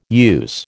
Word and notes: use: American English

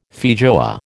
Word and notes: feijoa: American English

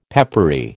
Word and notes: peppery: American English

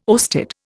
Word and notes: oersted: Indian

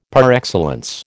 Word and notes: par excellence: American English

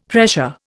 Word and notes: pressure: Indian

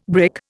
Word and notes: brick: Indian

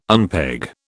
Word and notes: unpeg: British English

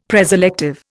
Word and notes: preselective: Indian

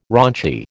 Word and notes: raunchy: American English

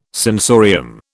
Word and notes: sensorium: British English

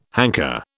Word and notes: hanker: British English

